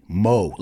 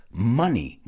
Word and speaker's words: money: So many of my students say money, mo, like M-O, but it's not pronounced like that. It's pronounced money, money. I think of other words that sound the same or other words that rhyme, and I think of funny.